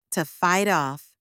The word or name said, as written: to fight off